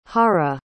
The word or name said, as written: jara